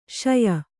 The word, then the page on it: ಶಯು: kannada